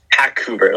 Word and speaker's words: Hackouver: Hackouver, like Hackouver. – Vancouver, the city.